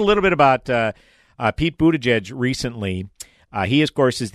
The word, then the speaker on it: Buttigieg: You know, it's pronounced Buttigieg.